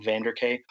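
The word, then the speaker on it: Vanderkaay: So the letters CK and then my name Vanderk, which sounds mostly like you would spell it, except there's two A's at the end, K-A-A-Y.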